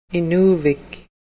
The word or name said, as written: inuvik